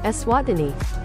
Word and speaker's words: Eswatini: Eswatini.